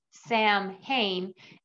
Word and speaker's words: Samhain: Summer's End is also known as Samhain. It's spelt Sam Hain and pronounced Samhain.